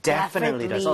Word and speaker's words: definitely: 자, 그런데 강세를 여러분들 이쪽에 줘보는 거예요. 이렇게 하게 되면 definitely 이렇게 앞쪽에 강세가 가면서 뒤에 틀리라고 할 여유가 안 생기게 돼요. 네. 그렇게 돼서 definitely 이런 이상한 발음이 아니라 definitely, definitely 이렇게 발음이 될 수밖에 없습니다.